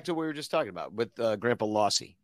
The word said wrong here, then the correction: Rossi: Rossi. Sorry. Always mispronounce that.